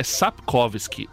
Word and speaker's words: Sapkowski: É, eu tentei até procurar, mas é Andrzej Andrei Andrei Sakovsky, acho que é isso. Ah, não, é Sapkowski. Andrei Sapkowski.